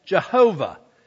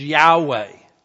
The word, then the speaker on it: Yahweh: we commonly refer to as Jehovah. Actually, probably the more correct pronunciation is Yahweh.